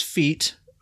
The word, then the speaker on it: Fete: This one's called Death Dealer's Feet. We looked that up. Feet is spelled F-E-T-E. And according to Davius, according to Google, it's pronounced feet.